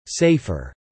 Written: safer

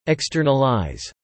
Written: externalize

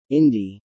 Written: indie

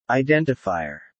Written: identifier

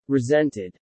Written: resented

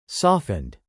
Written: softened

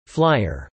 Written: Flyer

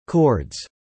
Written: chords

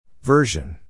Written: version

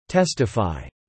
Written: testify

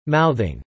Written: mouthing

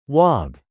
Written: Wog

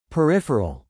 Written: peripheral

